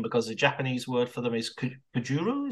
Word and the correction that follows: kaiju: is that right? – Kaiju.